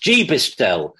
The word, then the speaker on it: GBISTL: It's GBISTL, pronounced GBISTL, meaning a person who got back into snooker during lockdown."